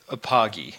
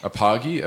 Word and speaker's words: Apogee: And for everyone else out there, this is Apogee. How would you say it? – Let's go with Apogee. – Apogee, okay.